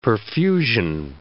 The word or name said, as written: perfusion